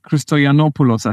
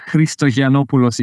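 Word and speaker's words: Christoyannopoulos: Yeah, Alex Christoyanopoulos, I think, would be in English. Christoyanopoulos, if you want to be Greek. I mean, anything close enough.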